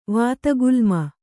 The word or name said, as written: ವಾತಗುಲ್ಮ